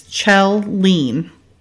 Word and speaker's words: Kjellin: The director is Alf Kjellin. It's spelled K J E L L I N, but I looked it up and it's Chell Lean.